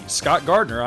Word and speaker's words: Scott Gardner: And hey, you can friend me, Scott Gardner, on Facebook too. My name is spelled S-C-O-T-T-G-A-R-D-N-E-R.